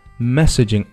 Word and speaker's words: messaging: Messaging app. Messaging. – 注意中间的哎，发音呢非常的小。OK，开口不大。 – Messaging app. 不要发成了 messaging app. No. Messaging. Messaging app.